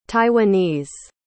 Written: taiwanese

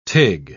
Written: tig

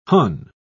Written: hon

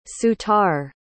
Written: soutar

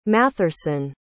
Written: matherson

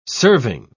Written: serving

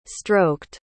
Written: stroked

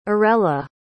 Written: arella

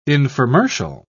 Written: infomercial

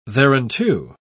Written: thereunto

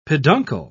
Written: peduncle

